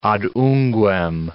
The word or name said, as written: ad unguem